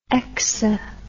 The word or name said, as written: exa